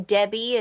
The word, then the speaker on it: Debby: My first name, Debbie, is spelled D E B B Y.